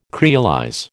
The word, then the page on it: creolize: American English